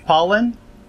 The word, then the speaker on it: Pollen: Is it pronounced Pollen? – Pollen, yeah. – Oh, okay. – Like the allergy.